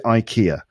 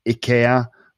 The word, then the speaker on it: IKEA: I pronounce it IKEA. In other places, I think you call it IKEA or other things.